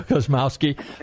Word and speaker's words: Kosmoski: Okay, there you go, Kosmoski.